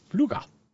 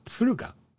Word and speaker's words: Pfluger: Herr Pfluger. Kind of tough to pronounce that little P there at the beginning before the F, but, you know, you can do it. And you can, Pfluga, just Pfluga.